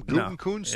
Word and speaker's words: Gutekunst: Gutekunst? – No, it is, yeah.